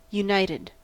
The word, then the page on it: united: American English